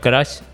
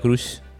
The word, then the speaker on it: crush: hasta hoy pensé que se pronunciaba crush. Sí, ya sé que van a decir, hay un guy, pero bueno. – Ah, no, no, no, un poco lo mismo. Eh, mucha eh, gente lo, pronuncia crush.